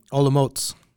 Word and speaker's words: Olomouc: Olomouc.